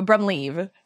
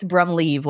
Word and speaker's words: Brumlev: It's close. So Brumlev. So I've heard Brumlev. All the different pronunciations throughout the years, but Blythe Brumlev, like you're leaving a party.